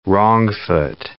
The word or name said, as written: wrong-foot